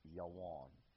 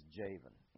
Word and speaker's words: Javan: So if you change that J to a Y and that V to a W, you have Yawon, the way I'm pronouncing it. Now I'll read it as Javan in this anglicized transliteration here.